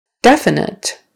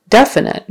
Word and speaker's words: definite: Definite, definite. Now it changes to the first syllable.